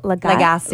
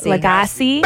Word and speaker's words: Lagasse: Emeril Lagasse. – Lagasse.